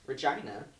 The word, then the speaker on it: Regina: B, Regina. C, that is how it's pronounced. – Yes. – I want to clarify that is how it's pronounced. Mm-hmm. That is how the locals say this is how you pronounce it.